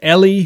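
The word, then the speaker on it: Ehle: You got Jennifer, I believe it's pronounced Ellie, who she plays the doctor who actually discovers the cure.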